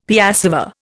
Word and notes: piassava: Indian

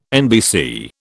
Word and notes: NBC: British English

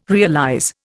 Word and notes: realize: Indian

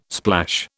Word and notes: splash: British English

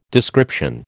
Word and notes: description: American English

